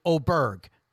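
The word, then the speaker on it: Åberg: Well, that's because Daniel Chopra, who's Swedish, I can't say it the way he says it, but Ludvig told everybody, because the pronunciations were just brutal, he just said, just call it me Auberg.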